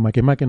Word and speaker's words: Makemake: de nuevo, Makemake, nombre hawaiano, repiten las dos últimas sílabas, ¿no? – Makemake o Muamua.